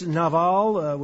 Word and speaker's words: Nabal: More likely in Hebrew, it's Nabal, with the V sound as opposed to a B.